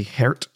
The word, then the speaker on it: Gert: This guy, Gert Melick, I think his name actually might be Hert. I'm not sure how you say it properly, but it's spelled Gert, G-E-R-T. Yeah. But it's definitely not Gert, but that's just the easiest way to say it.